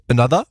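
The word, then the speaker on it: Anotr: Und ich weiß nicht, ob ich den Namen des Artists richtig ausspreche, aber Anotre featuring 54 Ultra. – Ich glaube, Another wieder ausgesprochen.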